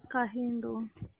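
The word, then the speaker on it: Kahindo: Cajindo.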